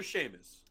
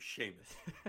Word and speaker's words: Seamus: Seamus or Seamus? Like, are you saying it incorrectly? – It's a C, like, you know, the ocean, and then yeah. – Mus, M-U-S. – S-E-A-M-U-S? – Yeah.